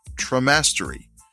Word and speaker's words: Traumastery: She has held academic appointments at UCLA and the University of Laverne and has recently developed Traumastery, rhymes with monastery or traumastery, a virtual education platform to assist survivors and professionals in becoming trauma-informed.